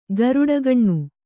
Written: ಗರುಡಗಣ್ಣು

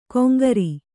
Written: ಕೊಂಗರಿ

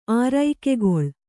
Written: ಆರೈಕೆಗೊಳ್

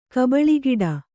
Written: ಕಬಳಿಗಿಡ

